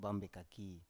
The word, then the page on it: bam bekaki: Biak